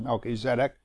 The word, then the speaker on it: Melchizedek: If I ask you of this obscure name, Melchizedek, many pronounce it Melchizedek, but Melchi is king and Zedek is righteousness, so it's the king of righteousness, Melchizedek.